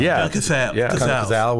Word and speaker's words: Cazale: Is it John Cazale? – John Cazale.